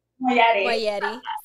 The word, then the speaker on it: Moayedi: Moayedi.